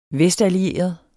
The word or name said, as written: vestallieret